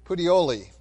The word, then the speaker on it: Puteoli: And after 1 day, the south wind blew, and the next day we came to Puteoli," that place. I always have a hard time pronouncing that one. "Puteoli," there we go, "where we found brethren, and were invited to stay with them 7 days, and so we went toward Rome.